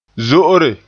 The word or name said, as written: zo'ore